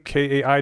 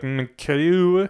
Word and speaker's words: MKAIWW: Uh, the guy whose name I couldn't figure out how to pronounce M K A I W W, and I was like MKU. Uh, apparently, call me Kai. The M and the W's on the end are uh, ignored.